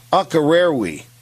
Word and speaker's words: Akureyri: We'll be visiting Grundarjordur and Akkamaur. I got that one wrong. Akkarerwi. Now that I said that correctly, Akkarerwi is a village in Iceland.